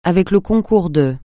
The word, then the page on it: avec le concours de: francais